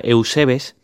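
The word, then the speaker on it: Eusebes (εὐσεβής): Pues bien, he ido al griego para ver esa palabra de piadoso, que es la 21-52, que se pronuncia Eusebes, y efectivamente era un hombre piadoso que se interesaba por las cosas de Dios Padre.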